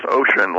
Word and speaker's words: Oisin: Well, I think a lot of us are would-be users, but the Olsen O-I-S-I-N. – Pronounced ocean, like ocean.